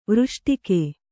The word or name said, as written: ವೃಷ್ಟಿಕೆ